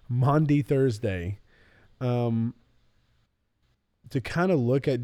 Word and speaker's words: Maundy Thursday: But this is going to come out on Maundy Thursday.